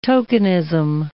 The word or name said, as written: tokenism